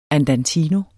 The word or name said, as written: andantino